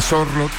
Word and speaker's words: Sorloth: en Sorloth, ahora sí lo dije bien.